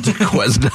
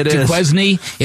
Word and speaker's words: Duquesne: And if you were in charge of Duquesne University. – Duquesne. – That's how it's spelled, Duquesne? – Right? – Duquesne.